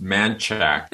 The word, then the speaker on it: Manchaca: There's a street that would hear pronounced Manchac. – Yes. – And I finally saw the street sign and it says Manchaca. And it's like, how did you get to Manchac from Manchaca?